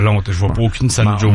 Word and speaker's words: San Jose: Puis un de nous deux, au lieu de dire San Jose, qui est la prononciation parfaite, un de nous deux aurait dit San Jose.